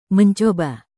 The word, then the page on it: mencoba: indonesian